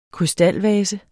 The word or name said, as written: krystalvase